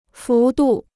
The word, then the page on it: 幅度: fú dù